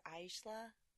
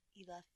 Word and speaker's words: Isla: Isla or Aisla? Isla, Isla Fisher.